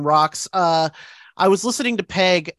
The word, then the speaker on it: Peg: Peggy. – One syllable too long. It's Peg.